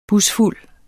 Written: busfuld

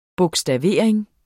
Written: bogstavering

